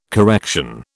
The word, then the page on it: correction: British English